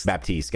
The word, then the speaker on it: Baptiste: So in 2019, they released the 30th hero on March 19th, which was, is it just Baptist? Is that how you say it? Or is it Baptista? – Baptiste.